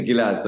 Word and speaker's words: Gilad: The actual name is Gilad, so you said it right.